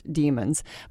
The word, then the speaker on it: daemons: And these are called D A E M O N S, pronounced demons, but they're an animal spirit.